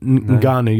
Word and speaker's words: Ngannou: Francis Nguyen. – No, not Ngannou. You're thinking of Ngannou.